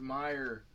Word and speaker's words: Mayer: It's Meyer, bro. – I'm pretty sure it's Mayer. – It's not. Yeah, it's not. – It's M-A-Y-E-R. – That is Meyer. It's pronounced Meyer.